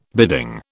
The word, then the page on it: bidding: British English